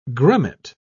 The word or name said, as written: grummet